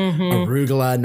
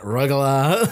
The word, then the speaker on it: arugula: She pronounced it arugula, not rugula.